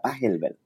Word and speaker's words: Pachelbel: Me decías que así se pronuncia, ¿verdad? Pachelbel. – Sí, es, es un compositor alemán. Yo creo que vas, eso es lo más real que creo que debe sonar Pachelbel pero a lo que dices, o sea, esa es una pieza que toqué chorro mil de veces.